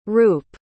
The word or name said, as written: roop